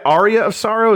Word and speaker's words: Aria of Sorrow: Yeah, Aria of Sorrow? Is that how you're supposed to say it? What did I say? Area of Sorrow? – Said it right.